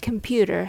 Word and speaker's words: computer: The word computer has three syllables. The rhythm goes tatada, computer. Let's sound out each part separately. First sound is k, k. Next one is n, n. And there's pew. And lastly, t and er, ter, ter. Computer, computer. The first and the last syllables are reduced, so they are very short and weak.